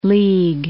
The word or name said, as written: League City